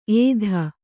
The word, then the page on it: ಏದು: ēdu